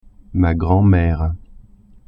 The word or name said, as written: grand-mere